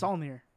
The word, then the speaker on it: Saulnier: Saulnier.